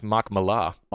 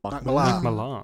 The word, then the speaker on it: Millar: I've just discovered it's pronounced Mark Millar. – Mark Millar. – There you go. I have been calling him Mark Millar forever, and then heard it pronounced the other day, and it was like, Mark Millar. I was like, sorry.